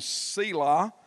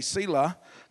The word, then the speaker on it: Selah: The first one's named Pause, and the second one named Selah, or Selah, according to how you say it. We say Selah because we got a granddaughter named that.